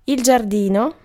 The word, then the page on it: giardino: [dʒ͡arˈd̪iː.no]; /dʒar.'di.no/